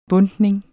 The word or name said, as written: bundtning